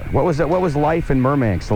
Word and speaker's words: Murmansk: Now, you came from a town called Murmansk.